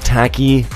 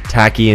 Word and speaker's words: Taiki: Anyways, these two, they call themselves Tacky and New Light. Yes, Tacky and New Light. Tacky is spelled T-A-I-K-I. And New Light, N-U-L-I-G-H-T.